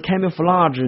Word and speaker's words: camouflage: 碰到这个单词，你的第一个感受是，这个单词为什么读成 camouflage 呢？如果我们不看音标的话，很可能读成 flage 或者 flag，它偏读 flage，所以独特的读音暴露了独特的来源，这是我们记忆单词一个非常重要的一个思维过程。